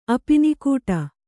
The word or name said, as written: ಅಪಿನಿಕೂಟ